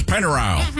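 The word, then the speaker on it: Pennyrile: Well, I grew up in a part of the world where it's spelled R-I-L-E because we pronounce it Pennyroyal. – Ah. – That's Pennyroyal, not Pennyroyal.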